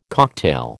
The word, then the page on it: cocktail: American English